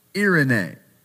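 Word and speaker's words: irene (εἰρήνη): It's pronounced irene.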